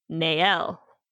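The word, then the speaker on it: Néel: No, it's spelled N E E L, but there's an accent on the first E. Nael.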